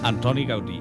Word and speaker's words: Gaudí: ¡Oh, Gaudí! – Lo he pronunciado un poco no catalán, pero... – Antoni Gaudí.